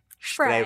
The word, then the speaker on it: Spree: It has been pointed out to me that the river that flows through Berlin, that I have been referring to as the Spree River, is actually pronounced Spree. – Spray River, something like that.